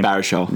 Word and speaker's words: Baruchel: And that's why all those guys are together, because Jay Baruchel, I keep butchering his name. – Baruchel.